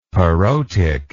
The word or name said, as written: parotic